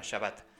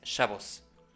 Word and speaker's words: Shabbat: Un pequeño y claro ejemplo de esta adaptación fonológica escuchamos en la pronunciación de la raíz hebrea Shin Bet Tav, que conocemos como Shabbat, en hebreo moderno por su pronunciación española, Shabbat, pero cuya pronunciación en yiddish europea del este de esta raíz hebrea es Shavos. Shabbat, Shavos. El hebreo moderno opta por las vocales abiertas comunes en la pronunciación latina, Shabbat, en lugar de Shavos, más eslavo germano.